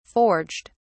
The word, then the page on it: forged: British English